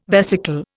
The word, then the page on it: vesicle: Indian